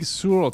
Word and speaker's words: Sur: Comment Big Sur, tu m'énerves. – Sur Oui, Sur. Ah. – non, mais ça s'écrit S-U-R. Ah, d'accord, c'est pas sourd, d'accord.